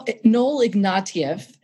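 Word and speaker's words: Ignatiev: Noel Ignatius, you talk about him. Why is he important? – Well, Noel Ignatieff.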